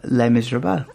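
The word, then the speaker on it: Les Misérables: Anne Hathaway, of course, is the star of Les Miserables.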